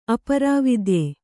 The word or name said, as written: ಅಪರಾವಿದ್ಯೆ